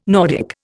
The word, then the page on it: Nordic: Indian